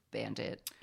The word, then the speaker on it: BVNDIT: It's spelled B-V-N-D-I-T, but it is Bandit.